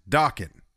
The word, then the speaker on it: Dokken: and it is pronounced Dokken. They confirmed.